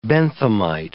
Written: Benthamite

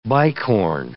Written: bicorne

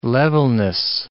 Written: levelness

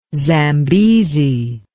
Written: zambezi

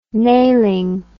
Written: nailing

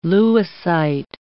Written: lewisite

